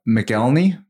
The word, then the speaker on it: McElhenney: And Rob McElhenney.